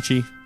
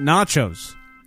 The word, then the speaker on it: nachos: Is that how you pronounce that? – Nachi, whichever. – Pronounce it nachos.